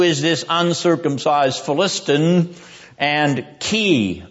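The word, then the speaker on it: key (כי): In the Hebrew text, as you will notice from your handout, there is a double key clause in this second question of David. That character, which is actually two letters in Hebrew, is pronounced key.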